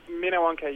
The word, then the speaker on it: Minnewanka: Is it Lake Minnewanka or Lake... – Minnewanka, yeah.